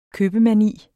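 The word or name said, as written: købemani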